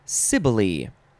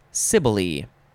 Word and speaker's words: Cybele: This word is pronounced sibile. It is spelled capital C-Y-B-E-L-E, sibile.